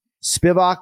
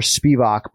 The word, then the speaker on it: Spivac: Spivak or Spivak, both count.